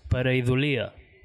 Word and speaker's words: Pareidolia: Vinga, es pronuncia Pareidolia, vale? – Joder, manxo. Com sou? Pareidolia, ok. I què collons vol dir això per un xernego de mitjor, que just sé això, no? De cotxiller. Què vol dir això, va? – Vale, a veure, una pareidolia és... en castellà és pareidolia. Pareidolia, ah, crec que... Però ho vam demanar a un... erudit lingüista amic nostre que mos va dir que ho havien de pronunciar pareidolia, vale?